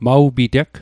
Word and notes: maw piteg: Mi'gmaq

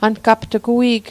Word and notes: angapt'g: ankapt'k

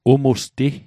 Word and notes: umusti: Mi'gmaq

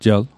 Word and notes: jel: Mi'gmaq

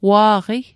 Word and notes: wa'qi: Mi'gmaq

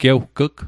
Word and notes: gewg'g: Mi'gmaq

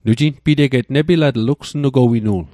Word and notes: nepilatl: Mi'gmaq

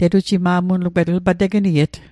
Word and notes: tegniet: Mi'gmaq

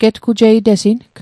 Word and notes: getgujeitesing: Mi'gmaq

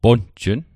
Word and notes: ponjn: Mi'gmaq

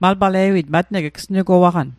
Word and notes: matng: Mi'gmaq

